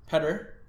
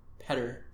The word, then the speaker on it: Petter: Peter, oh. Petter, Mrazek. – Yeah. Petter. Petter Mrazek.